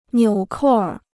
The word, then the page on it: 纽扣儿: niǔ kòu ér